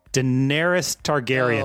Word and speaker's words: Daenerys Targaryen: Daenerys Targaryen.